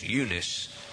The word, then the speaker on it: Eunice: It's Eunice, I think, is the way it's pronounced now.